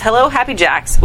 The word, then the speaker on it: Happy Jacks: That's Hello Happy Jacks with the Jacks as a as in a card game.